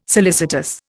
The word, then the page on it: solicitous: Indian